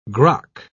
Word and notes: grok: British English

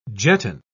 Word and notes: jetton: British English

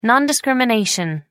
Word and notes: non-discrimination: American English

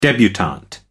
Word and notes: debutante: American English

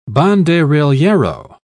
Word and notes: banderillero: British English

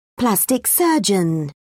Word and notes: plastic surgeon: British English

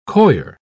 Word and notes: coir: British English

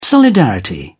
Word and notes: solidarity: British English

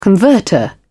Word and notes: converter: British English

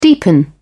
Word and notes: deepen: British English

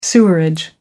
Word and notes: sewerage: American English

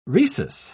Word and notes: rhesus: British English

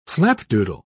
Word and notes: flapdoodle: British English

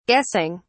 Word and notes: guessing: British English

